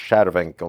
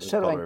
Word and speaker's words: Cervenka: Vi släppte ett avsnitt om Anders Cervenka, om jag ska uttala det rätt. – Fast han Cervenka. Det fel. Cervenka. – Det är tjeckiskt. – Ja, Cervenka.